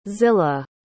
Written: zila

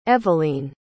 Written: evaleen

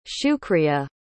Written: shukriya

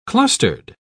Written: clustered

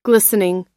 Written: glistening

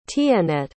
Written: tienette

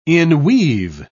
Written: inweave

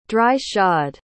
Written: dry-shod

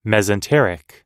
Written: mesenteric

